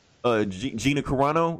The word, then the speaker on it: Gina Carano: And plus, we hadn't even seen Gina Carano.